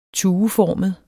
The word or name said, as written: tueformet